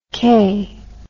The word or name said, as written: kaye